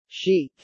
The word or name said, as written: Chic